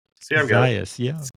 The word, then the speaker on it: Zajas: Zajas, yeah, you got it now, because the phonetic thing helps. Girls, people are like Zajas, Zajas. – I thought it was like Spanish, the soft J.